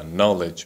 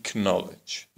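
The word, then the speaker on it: knowledge: تلفظ کلمات انگلیسی مثل نایف به معنی چاقو نی به معنی زانو و نالج به معنی دانش رو اینطوری تلفظ میکردند کنایف کنی کنالج و بقیه اون کلماتی که با کی هم شروع می شدن ولی کی خونده نمی شد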